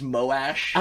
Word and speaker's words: Moash: Yeah, no, I remember, last chapter, you said Mosh. It is Moash.